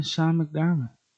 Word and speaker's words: Sean McDermott: And I just don't believe in Sean McDermott. Did I say his name right? – Yeah, you did.